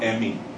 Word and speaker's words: Emi: In Yoruba, E's are pronounced like E, like you would say for egg. It's E. Okay? And I's are pronounced like E, like in feet. All right? So Emi is spelled E-M-I. Emi.